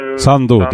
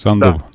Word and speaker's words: Санду: Как вы считаете, если побеждает Санду или Санду, где, кстати, правильно ударение ставить? – Обычно ставят на последний... – Санду, да, то есть Санду.